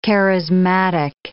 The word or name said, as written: charismatic